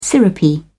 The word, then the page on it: syrupy: British English